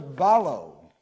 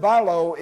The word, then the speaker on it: ballo: And that word ought to be an easy word for all of us to remember because it is spelled B-A-L-L-O, almost like our English word ball. And the meaning of that word balo is to throw or to cast, the same way that we might do with a ball.